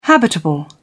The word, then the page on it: habitable: British English